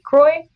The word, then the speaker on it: Croix: But my name is spelled C-R-O-I-X, like this shitty bubbly water. P-R-O-V as in Victor, E-N as in Nancy, C-E, Croy Provence.